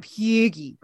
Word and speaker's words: hygge: Well, on that note, for a long time here at Satellite Sisters, we've been tracking the Norwegian tradition of hygge, right? – wonderful pronunciation this morning.